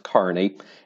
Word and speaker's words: Kearney: So yes, listeners who are not familiar with Nebraska, first, it's pronounced Kearney, and second, it is a real place.